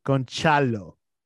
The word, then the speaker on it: Gonçalo: Maybe this new guy that scored a hat-trick yesterday, you know, he will be able to put 60 million for Gonzalo Ramos. – Gonçalo. Say it in Portuguese. – How do you say? – Gonçalo. – Gonçalo. Okay.